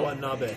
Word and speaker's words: Watanabe: Number eight, Grant Watanabe. I said it right. – No, Watanabe. – I said Watanabe. Like Guantanamo. Watanabe.